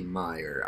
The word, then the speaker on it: Mayer: Hitting it rhymes with tire, which would be Mayer.